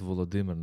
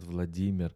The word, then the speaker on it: Volodymyr: Volodymyr, not Vladimir, as in Russia.